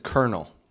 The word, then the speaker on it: colonel: I would love to know why that is spelled like that, because there is no R in colonel.